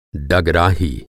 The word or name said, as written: डगराही